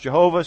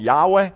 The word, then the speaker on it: Yahweh: Sometimes it's pronounced Jehovah. Sometimes I always call it Yahweh, which is probably a more direct translation.